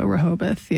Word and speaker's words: Rehoboth: Is it Rehoboth? That's how you say it, right? – I think so, Rehoboth, yeah.